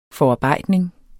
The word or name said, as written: forarbejdning